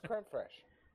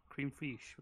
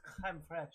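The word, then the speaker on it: crème fraîche: No, it's creme fraiche. Or creme... – Cream fiche.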